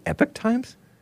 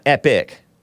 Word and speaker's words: Epoch: So, he's not, I guess he's boycotting the United States of America, according to this Epoch Times, or Epoch is what it looks like, but I learned from the great Bill Lockwood on his show a few years ago that it's pronounced Epoch Times. I even looked it up to confirm, because I was like, is it really Epoch Times? Not epic, epoch. Epic is different.